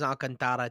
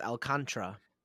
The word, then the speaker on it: Alcántara: It's Alcantara. – No, it's not. Look it up. I thought it was Alcantara too, but it's Alcantara.